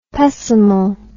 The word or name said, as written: pessimal